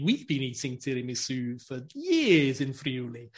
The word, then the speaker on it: tiramisu: And he created this thing called tiramisu, not tiramisu, because he spoke Venetian dialect, and that's how you say it in Venetian dialect.